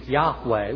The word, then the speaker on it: Yahweh: Now, they may say, well, you may want to pronounce it in Hebrew, Yahweh, which is probably more accurate, by the way, Yahweh or Yahweh.